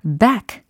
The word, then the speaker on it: back: back. 끝소리 기억나시죠? 요거 bag가 아니라 back. 라고 발음해 주셔야 되고요.